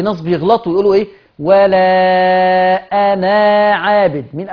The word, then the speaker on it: أنا: ألف أنا بالذات في القرآن لا تنطق وصلا، أدي قاعدة قلته، لا تنطق، كلامي واضح، لا تنطق ألف أنا وصلا. ولا أنا عابد، أنا عابد، كأني نون مفتوحة بس، أنا عابد ولا أنا عابد ما عبدتم.